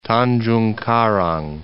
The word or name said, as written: Tanjungkarang